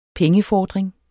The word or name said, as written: pengefordring